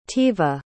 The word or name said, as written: Tiva